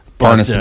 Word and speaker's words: Parnas: Parnas? – No, I'm not really sure how to say it. – Lev Parnas, I believe it is. – Parnas, it is.